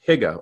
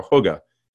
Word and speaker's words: hygge: They have something that's called a HIGA or HUGA. It's spelled H-Y-G-G-E.